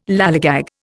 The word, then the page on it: lallygag: Indian